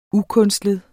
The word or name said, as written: ukunstlet